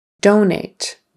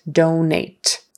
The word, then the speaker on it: donate: Donate, donate. So it's a long O, do, and then a long A, nate, donate. You don't want to say donut, because that's something you eat, right? Very good in the morning for breakfast, donut. We want donate, donate. And the emphasis is on the first syllable, donate. Money, money.